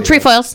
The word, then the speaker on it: Trefoils: Thin Mints, Samoas, Trifles. I don't know how you say it. Oh, Trefoils.